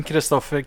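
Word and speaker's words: Kristoffer: So Kim Christopher Kami.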